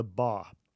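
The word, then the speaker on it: Ba (霸): as the Ba.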